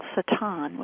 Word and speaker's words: Satan: Hebrew's word Satan, which is how you would pronounce it in Hebrew, means adversary, generally speaking.